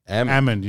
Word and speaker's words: almond: I used to pronounce it almond. – Amund, you just, like, drop the L.